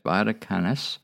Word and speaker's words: Vaticanus: A second codex is, and I'm going to spell it, V-I-T-I-C-A-N-U-S, Vaticanus.